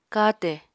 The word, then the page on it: katè: Apatani